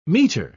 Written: meter

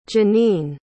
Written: Jenene

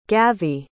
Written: gavi

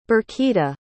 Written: birkita

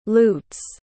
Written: lutes

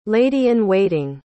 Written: lady-in-waiting

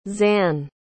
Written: Zan

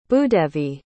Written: bhoodevi

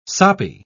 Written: soppy

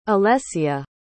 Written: alessia

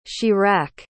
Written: chirac

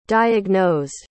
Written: diagnosed

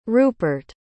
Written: rupert

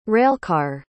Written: railcar

